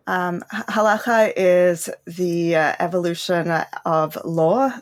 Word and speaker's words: halakha: There is Jewish law called the halakha. Am I saying that correctly? – You got to get that guttural ch in, but it's basically correct, halakha.